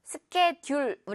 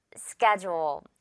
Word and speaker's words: schedule: 스케 다음에 듀얼처럼 생겼는데 그렇지 않죠. 스케듀얼, 우리 이렇게 발음하지 않죠. 스케듀얼, 듀얼, 이렇게 지읒 발음 나잖아요. 바로 이거예요. D의 반전, schedule.